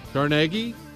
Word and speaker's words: Carnegie: They were the men who did steel, Carnegie.